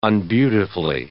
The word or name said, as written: unbeautifully